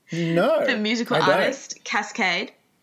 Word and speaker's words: Kaskade: It is K-A-S-K-A-D-E. Mm-hmm. Cascade.